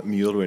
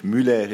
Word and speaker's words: Müller: Well, it's Tom Müller in English, Müller in French, and Müller in German.